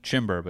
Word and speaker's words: Chimbor: It was Chimber, but yeah. – Chimber. – The O can be an E. It can be an O.